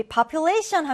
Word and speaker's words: population: 자, 이 단어는 우리 이 발음 기호를 잘 보고 2강세 그리고 1강세 지켜서 population입니다. 우리 population 하면 이건 인구, 특정 지역이나 집단의 개체 수를 얘기합니다.